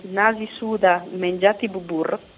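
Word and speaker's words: Nasi sudah menjadi bubur: Infatti un loro proverbio molto usato, che in indonesiano, te lo pronuncio bene, si dice Nasi Suda Menjati Bubur,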